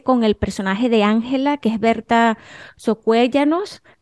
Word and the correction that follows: Socuellamos: lo dije bien. – Socuellamos. – Socuellamos,